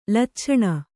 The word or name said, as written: ಲಚ್ಛಣ